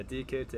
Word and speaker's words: Diakite: Is that how you say his name? – Yeah, Diakite, yeah.